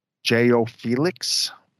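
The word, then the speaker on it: João Félix: J.O. Felix.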